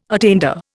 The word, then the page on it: attainder: Indian